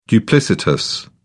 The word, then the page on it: duplicitous: British English